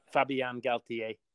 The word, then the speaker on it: Fabien Galthié: So they're coached by Fabien Galthié. I'm not going to pronounce his name properly. – Fabien Galthié.